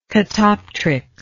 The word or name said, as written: catoptrically